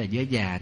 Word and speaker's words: giới già: Đó, cái thứ hai là ngăn cái sự phạm trọng giới, giới già, chúng ta hay còn gọi là giới giá, giá giới đó, tánh giới và giá giới, nhưng đúng là chúng ta đọc là già. Cái chữ đó là giới già, tức là để ngăn chặn cái sự cơ hiềm.